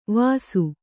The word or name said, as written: ವಸು